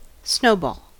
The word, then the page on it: snowball: American English